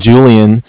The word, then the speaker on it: Julian: My name is Julian. And it's spelled J-U-L-I-A-N.